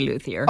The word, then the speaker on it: luthier: I thought it was luthier. Is that correct? – Or yeah, is it? Well, yes, it is, people say luthier.